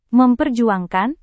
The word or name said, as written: memperjuangkan